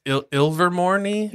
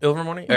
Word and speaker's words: Ilvermorny: It's Ilvermorny? – Yes, that is correct. – Okay. Is that how you pronounce it? – Yep. – Ilvermorny? – All right.